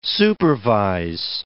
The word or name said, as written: supervise